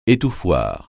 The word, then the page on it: étouffoir: français